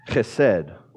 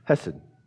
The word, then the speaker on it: chesed: That's the word in Hebrew pronounced chesed, or as we would say in America, hesed.